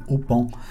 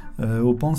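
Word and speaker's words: Ô Paon: Et cette semaine, on te conseille d'écouter Opan. Opan, ça s'écrit O avec un accent circonflexe, et puis Pan, comme l'oiseau, le Pan. Opan, c'est l'un des projets musicaux de Geneviève Castré, qui en avait beaucoup des projets.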